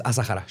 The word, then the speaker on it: Asahara: He pronunciado su nombre diferente cada vez. Es pues, Choco a Sahara, ¿no? Choco, sí, pero a veces digo a Sahara y a, pero es a Sahara.